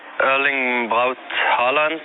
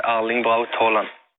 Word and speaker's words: Erling Braut Haaland: If you're not in Norway, you can say Erling Braut Haaland. And if you're in Norway, you can say Erling Braut Haaland.